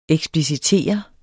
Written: eksplicitere

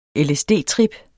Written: lsd-trip

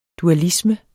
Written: dualisme

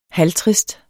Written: halvtrist

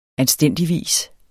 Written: anstændigvis

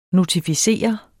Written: notificere

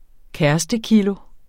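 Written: kærestekilo

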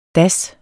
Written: das

